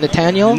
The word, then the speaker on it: Netanel: Is it Netanel? – Netanel, yeah.